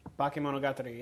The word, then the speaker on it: Bakemonogatari: Again, I'm going to slaughter the pronunciation of this, but Bakemonogatari. – Bakemonogatari, yeah.